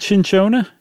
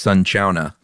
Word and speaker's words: cinchona: Is it cinchona? – No, it's neither. That's so us. I just looked up real quick. It says it's sunchona.